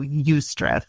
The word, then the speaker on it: eustress: There's also eustress, which is really exciting because it's not all bad. – Eustress, Y-O-U, is that what you're saying? – It's spelled E-U-S-T-R-E-S-S. – Eustress. Okay.